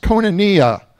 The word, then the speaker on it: koinonia: In the Greek, the word is pronounced koinonia.